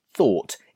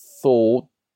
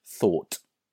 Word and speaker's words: thought: And it's important that you say thought. It's not thought, thought, or something like that. You need to make sure you put the strong t sound at the end, thought.